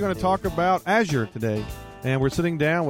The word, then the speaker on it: Azure: So in the U.S., it's pronounced Azure. We went and we looked it up. We looked it up in like 10 dictionaries and whatever, and the word was Azure, with emphasis on the first one. Everywhere else in the world, like every other country, it's Azure.